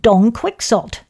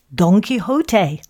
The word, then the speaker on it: Don Quixote: You said Don Quixote. It's pronounced Don Quixote.